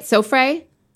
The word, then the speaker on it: Sofra: Sofra? – Sofra, yeah.